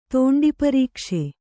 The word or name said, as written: ತೋಂಡಿಪರೀಕ್ಷೆ